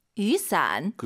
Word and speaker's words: 雨伞: 우산 두 글자로 雨伞. – 그 비오다 할 때 와유 발음이요. 예, 위 아니고 유.